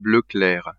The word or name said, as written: bleu clair